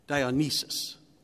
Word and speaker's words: Dionysus: It is said in Greek mythology, the god of harvest, Diothanes, he would celebrate by having four tigers pull his chariot. I think it was pronounced Dionysus is the correct pronunciation.